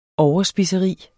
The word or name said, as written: overspiseri